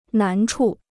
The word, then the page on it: 难处: nán chu